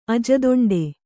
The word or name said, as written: ಅಜದೊಂಡೆ